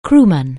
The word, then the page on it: crewman: anglais